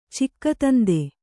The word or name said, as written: ಚಿಕ್ಕತಂದೆ